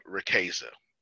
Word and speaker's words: Riqueza: It is called Riqueza. That's the English pronunciation of it. It is not pronounced that way in Spanish, which is where I got the word from.